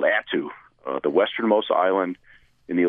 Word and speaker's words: Attu: So I believe I read that this is how you got out to Attu. Is that how I pronounce it? – That's right. That's Attu.